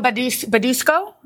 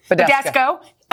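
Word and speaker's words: Badescu: This is Mario Badusco. Is that how you say it? – Badesco.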